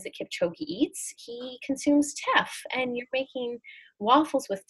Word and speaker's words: Teff: And your teffles, your waffles made with Teff, did I pronounce that right? – Yeah, you did.